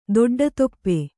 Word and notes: ದೊಡ್ಡತೊಪ್ಪೆ: Kannada

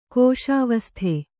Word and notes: ಕೋಶಾವಸ್ಥೆ: kōśāvasthe

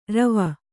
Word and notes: ರೋವ: rōva

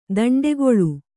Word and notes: ದಂಡೆಗೊಳ್ಳು: daṇḍegoḷḷu

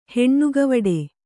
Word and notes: ಹೆಣ್ಣುಗವಡೆ: Kannada

